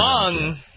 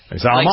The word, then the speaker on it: Salman: S-A-L-M-A-N is how you pronounce the name. Salmon. – Salmon. – Salmon. – Like Salmon Rushdie.